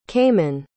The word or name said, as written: kaemon